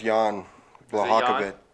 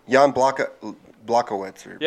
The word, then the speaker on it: Jan Blachowicz: No, it's Jan Blachowicz. – Jan Blachowicz. – Corey Anderson says he's the next big thing in the heavyweight. So. – Jan Blachowicz. – Yeah, you're saying that right.